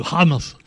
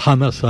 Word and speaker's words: Hamas: Le Hamas, voilà, faut dire comme ça, faut prononcer le Hamas comme Elisabeth Lévy ou Pascal Pro, le Hamas parce que c'est des méchants. Avec un H comme ça, tu vois, tout de suite ça fait plus, ça fait plus méchant.